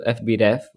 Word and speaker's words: FBref: So at the end of the season, according to FBref, which is how you say it, their expected goal difference was -3.